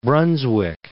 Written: Brunswick

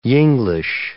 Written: Yinglish

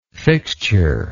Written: fixtureless

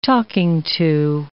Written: talking-to

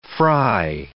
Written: fry

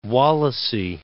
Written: Wallasey

